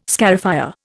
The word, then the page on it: scarifier: Indian